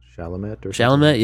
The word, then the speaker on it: Chalamet: Chalamet, I think is what it's pronounced, I think. – Chalamet, yeah, for Call Me By Your Name.